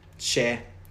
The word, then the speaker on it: Cher: It's share. She shares herself with everyone. She said share at her concert. We've gone to her concert and you still don't pronounce her name right.